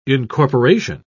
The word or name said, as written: incorporation